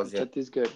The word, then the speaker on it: Chhattisgarh: And Chhattisgarh. I don't know. Forgive me if I did not pronounce well. – Chhattisgarh, that's right. Yeah.